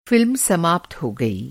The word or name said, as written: समाप्त